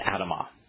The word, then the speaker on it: Adamah: And ground here is Adamah. It's written the same way as Adam, with the addition of the Hebrew he, which is pronounced ah at the end of the word.